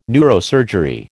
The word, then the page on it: neurosurgery: American English